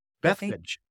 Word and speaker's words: Bethphage: Now, when they drew near Jerusalem to Bethphage, how do you say that? – Yeah, Bethphage. Yeah.